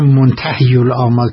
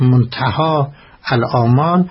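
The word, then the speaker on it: منتهی‌الآمال: البته بعضی هایی توجه ندارم منتهی الاعمال تلفظ میکنن که صحیحش منتهاست، منتهی الاعمال.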